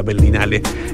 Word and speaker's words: Berlinale: al mejor guión en la Berlinale, siempre me cuesta Berlinale, ahí lo dije bien, del 2017.